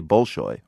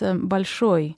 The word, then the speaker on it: Bolshoi: Now, most people, like myself, say Bolshoi, wrong? – Well, in Russian, it's stressed on the last syllable, so it's Bolshoi. In that sense, you would anglicise it as Bolshoi.